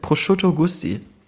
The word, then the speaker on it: Prosciutto Gusti: Nee, Prosciutto wie Schinken und Gusti wie Gustav Drüner ist doch mein alter Ego.